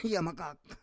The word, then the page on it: yamakak: Roon